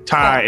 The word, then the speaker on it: Ty: Her name is Tia Abrams. Did I say that right? – Ty Abrams. – Like Thai food, the tie that you wear, all of those things. – Okay, cool. Ty Abrams.